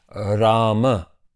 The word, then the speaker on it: Rama: In Sanskrit, it would be pronounced Rama, with the last letter fully pronounced.